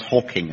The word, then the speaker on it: Hawking: And how would you kill Stephen Hawkins? – Quite easily. I mean, he's... Before we go any further here, there will be complaints about that material. Because it's pronounced Hawking and not Hawkins.